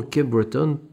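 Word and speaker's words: Cape Breton: Mais nous restons sur le continent américain, mais au Canada, à Cap-Breton, Cape-Breton, peu importe comment vous voulez le prononcer,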